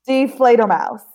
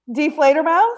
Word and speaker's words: Die Fledermaus: Die Fledermaus. – Isn't deflator like die, D-I-E, and then flader, like F-L-A-D-E-R, and then mouse, like mouse, the little mouse. Die Fledermaus.